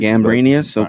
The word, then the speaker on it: Gambrinus: And I'm going to put, how would you say that? – Yeah, that Gambrinus? – Gambrinus? – That's how I would say it. – Gambrinus, okay.